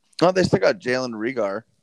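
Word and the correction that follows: Reagor: Rigar. I don't think I said his name right. Rieger. Uh, Rieger.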